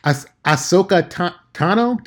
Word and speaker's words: Ahsoka Tano: I personally am hoping against hope that that might be an Ahsoka Tano. Ahsoka Tano? Did I say that right, Aaron? – Yeah.